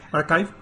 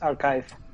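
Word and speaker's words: arXiv: Archive, con X. Sí. – Con X, sí. La pronunciación que he escuchado y que todo el mundo usa, incluyendo los que teníamos duda, es Archive.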